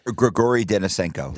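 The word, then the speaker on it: Grigori Denisenko: The Florida Panthers, if you didn't know, lost their 2018 first-round pick, Gregorio Denisenko. Is that the way you pronounce it? – Grigory Denisenko.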